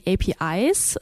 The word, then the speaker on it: APIs: Und äh, ein wichtiges Stichwort sind da die APIs. Ähm, spreche ich richtig aus, wenn ich es Englisch ausspreche, ne? – Ist, äh, ich glaube, Common Sense, das Englisch auszusprechen, ja.